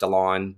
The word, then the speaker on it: DeLaune: You can find me on LinkedIn, Eric DeLaune, D-E-L-A-U-N-E.